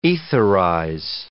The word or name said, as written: etherize